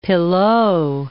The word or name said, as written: pilau